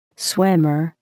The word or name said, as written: swimmer